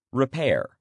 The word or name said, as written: repair